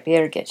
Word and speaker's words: Birgit: Birgit. She said the first syllable, beer. Birgit.